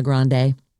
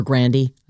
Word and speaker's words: Grande: Nona Grande, or Grandy, I think is how she says you actually say her name.